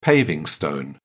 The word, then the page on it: paver: British English